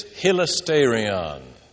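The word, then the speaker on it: hilasterion (ἱλαστήριον): It's hilasterion.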